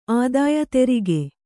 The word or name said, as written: ಆದಾಯ ತೆರಿಗೆ